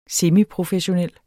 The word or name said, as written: semiprofessionel